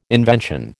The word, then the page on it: invention: American English